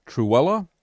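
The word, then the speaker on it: Trewhella: Again, the book is The Doctrine of the Lesser Magistrates by Matthew Truella. Did I pronounce it correctly that time? And you can find it at... – You did.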